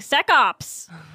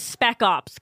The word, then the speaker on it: spec ops: But it was just, like, I kept pronouncing this one word wrong. It was, like, spec ops, and I kept saying sec ops. And they're like, it's spec ops.